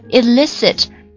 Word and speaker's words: elicit: 好，我们来看一下例句当中出现了前面一个 illicit, E-L-I-C-I-T, 它是过去时又加了 ed, elicited. 这是一个动词，表示引出、探出。它跟我们学的这个 illicit, 它的读音是完全一样的。